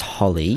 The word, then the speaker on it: Holi: And one of them, which I found very interesting, is called, do you think it's Holi or Holly? – My pronunciation is terrible. I believe that it's pronounced Holi. – Holi.